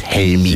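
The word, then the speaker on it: Helmig: Hugo Helmy, geschrieben mit G hinten dran, aber Hugo Helmy ausgesprochen auf Dänisch. Und deswegen sagen wir es auch so. That's why we pronounce it Helmy.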